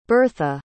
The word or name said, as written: birtha